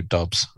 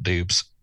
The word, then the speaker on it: Doubs: And Romeo Dobbs, not Dubs. It's spelled D O U B S. It always throws me off.